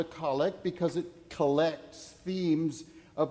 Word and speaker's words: collect: You notice the name of this kind of prayer is pronounced collect, with the accent on the first syllable, not as normal in English, with the accent on the second syllable, collect.